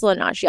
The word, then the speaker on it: Laneige: It's Laneige, yeah.